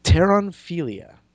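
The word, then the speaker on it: Pteronophilia: Pteranophilia. – Pteron? – Pteron. But it's spelled like pterodactyl. There's a silent P at the beginning.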